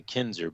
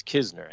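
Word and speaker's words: Knizner: Keep wanting to say Kinzer, but it's Kisner, Andrew Kisner.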